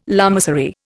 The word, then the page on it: lamasery: Indian